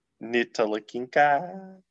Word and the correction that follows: Ntilikina: How do you say that name? – Ntilikina.